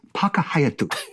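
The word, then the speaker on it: Paka Hayatu (パークハイアット): No, that hotel is called the Park Hyatt, or in Japanese, Paka Hayatu. That's how you say it.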